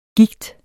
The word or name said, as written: Gigten